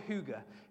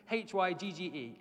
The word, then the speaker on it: hygge: It's called hygge. H Y G G E. It's actually pronounced hygge. I looked it up.